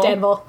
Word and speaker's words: Danville: It's spelled Danville, but it's Danville. – It's Danville, yeah. – Like an anvil.